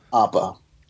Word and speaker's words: Appa: Forgive me if I pronounce it incorrectly, but is it Appa or Appa? – Appa.